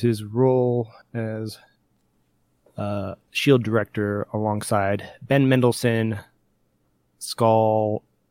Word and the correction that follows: Skrull: Supposed to be Skrull. You're missing an R.